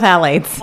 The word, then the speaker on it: phthalates: Puff of phthalates. – I hate that word, and I have to say it all the time. – It's like Michelle Pfeiffer. The P is silent.